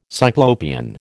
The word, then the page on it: Cyclopean: American English